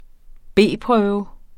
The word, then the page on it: B-prøve: ˈbeˀˌpʁœːwə